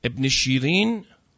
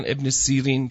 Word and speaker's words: ابن سیرین: و یا در مورد مثلا ابن سیرین، معبر بزرگ که اسم مادر شیرین بود، ابن شیرین که حالا تلفظ میکردن ابن سیرین، چون بعضی وقتا شین و سین،